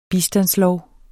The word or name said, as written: bistandslov